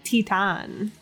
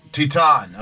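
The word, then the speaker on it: Titane: How's it pronounced? – Titan. – Titan. Okay.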